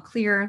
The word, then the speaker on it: Xlear: But it's called Clear. There's a little elephant on the package and it's spelled X-L-E-A-R.